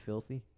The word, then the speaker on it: Phillthy: His name, I may be pronouncing this wrong, but it's literally spelled P-H-I-L-L-T-H-Y. So would you think that's pronounced filthy? – Let me see it.